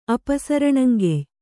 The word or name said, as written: ಅಪಸರಣಂಗೆಯ್